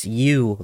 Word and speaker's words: ewe: She said, it's you, like the female sheep, spelled E-W-E,